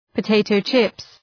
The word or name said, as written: potato chips